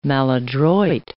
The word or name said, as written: maladroit